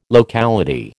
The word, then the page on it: locality: American English